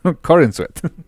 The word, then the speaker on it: Corenswet: No, Corenswet. – Sí, porque Corenswet es como cuando yo como muchos esquites. – Ándale, así. Exacto, Corenswet básicamente son esquites, ¿no?